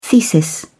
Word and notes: thesis: British English

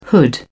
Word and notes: hood: British English

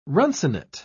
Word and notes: runcinate: British English

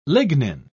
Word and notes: lignin: British English

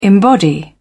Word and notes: embody: British English

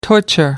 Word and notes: torture: American English

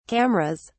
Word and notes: cameras: British English

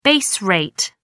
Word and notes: base rate: American English

